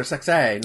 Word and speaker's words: Sexay: It's Grandmaster Sexay, not Sexy. It's Grandmaster Sexay.